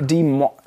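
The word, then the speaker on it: Des Moines: Die kommen aus, genau, aus, ich kann es nicht aussprechen, Die Moines. Moines. – De, ah, ja, ja. De, ja. De Mo, also es sieht so französisch geschrieben aus. Demo, aber es, Demo, de, Demo, ja.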